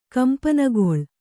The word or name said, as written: ಕಂಪನಗೊಳ್